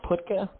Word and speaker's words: Putka: It's actually the latter pronunciation is, I guess, more correct, Putka.